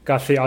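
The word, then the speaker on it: García: but the only part that I say in a Spanish accent is Garcia. I say Mario Garcia Romo.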